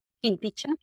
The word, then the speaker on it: Quinticha: porque la Q como en quechua se pronuncia como Quinticha.